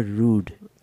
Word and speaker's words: Ruud: And it will be Djokovic against Casper Ruud. – You're right. – That would be a rude awakening for Casper. – It's spelled R-U-U-D.